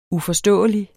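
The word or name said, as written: UFORSTÅELIGE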